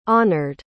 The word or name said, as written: honored